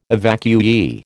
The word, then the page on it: evacuee: American English